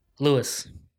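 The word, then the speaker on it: Luis: Lewis.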